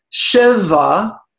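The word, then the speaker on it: sheva (שבע): But the number seven is in Hebrew pronounced sheva, sheva.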